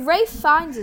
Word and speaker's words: Ralph Fiennes: Anyway, Ralph Fiennes is great in this.